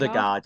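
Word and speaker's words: Ødegaard: Is it Odegaard? – Odegaard, yeah.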